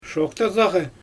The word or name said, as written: ʃoxtazaaɣɛ